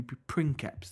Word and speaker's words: Princip: We say Princep, but in his native, it'd be Princeps.